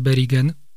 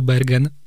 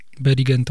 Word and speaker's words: Bergen: I teraz właśnie specjalnie przechodzę do mm, kolejnego miasta na północy, miasta Bergen, albo po polsku Bergen. Bergen to wymowa norweska w którym podobno legenda miejska głosi, że pada praktycznie 11 miesięcy w roku.